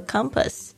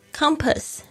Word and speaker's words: compass: 成为你的指南针 Let 就是让 Your heart 你的心 Be your compass 成为你的指南针 Let your heart be your compass 这边呢我们稍微注意一下两个字的发音一个是 heart H-E-A-R-T 不要念成 heart Heart Compass C-O-M-P-A-S-S 特别注意一下前面的这个 C-O-M Come 然后后面这个 A 的发音呢是发的声音 Compass Compass 指南针好的这一个留言不是格言是格言不是留言哎呦现在这个时间有点晚了所以就是这个精神呢有点不济的状态哦。